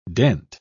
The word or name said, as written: dent